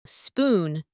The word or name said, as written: spoon